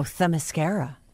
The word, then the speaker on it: Themyscira: Yeah, Themyscira. – Oh, Themyscira.